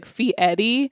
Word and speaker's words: Fieri: He said, I always say Fieri, and then he said he explained how to pronounce it. It's like you're saying the name Eddie, just like Fee Eddie.